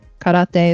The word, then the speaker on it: karate: Karate? No, it's not karate. It's karate.